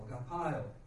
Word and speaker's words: agapao: And so phonetically you see it spelled A-G-A-P-A-O, agapio.